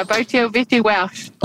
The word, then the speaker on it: Botille Vette Walsh: And I think last time I butchered your name, Botille, Vette, Walsh. How did I go this time? – Yeah, getting better. Botille, Vette, Walsh. – Oh, yeah, the Vette. No, I haven't got the Vette at all yet.